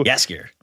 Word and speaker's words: Jaskier: Jaskier. – Jaskier, is that how it's pronounced? – Yeah.